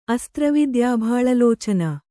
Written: ಅಸ್ತ್ರವಿದ್ಯಾಭಾಳಲೋಚನ

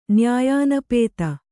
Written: ನ್ಯಾಯಾನಪೇತ

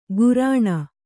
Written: ಘೂರ್ಣ

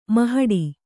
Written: ಮಹಡಿ

